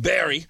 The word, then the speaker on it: Barre: It's spelled B-A-R-R-E. It's in Western New York, but they call it Barrie.